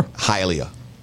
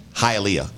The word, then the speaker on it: Hialeah: Hialeah. – One more time. – Hialeah. – Hialeah. – Hialeah. Hialeah. Isn't it spelled H-I-A-L-E-A-H? Something H-I-A. If you know a girl named Leah, you'd be like, Hialeah.